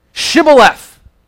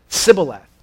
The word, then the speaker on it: Shibboleth: Shibboleth. But the people of Ephraim could not pronounce the SH. They would say Sibboleth.